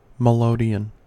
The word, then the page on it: melodeon: American English